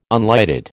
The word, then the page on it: unlighted: American English